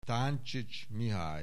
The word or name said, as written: Táncsics Mihály